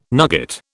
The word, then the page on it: nugget: British English